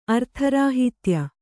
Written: ಅರ್ಥರಾಹಿತ್ಯ

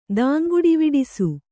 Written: ದಾಂಗುಡಿವಿಡಿಸು

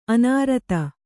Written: ಅನೂರಾಧ

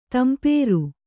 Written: ತಂಪೇರು

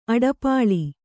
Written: ಅಡಪಾಳಿ

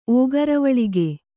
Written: ಓಗರವಳಿಗೆ

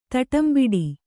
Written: ತಡಂಬಡು

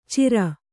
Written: ಚೀರ

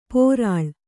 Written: ಪೊರಳ್

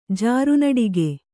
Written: ಜಾರುನಡಿಗೆ

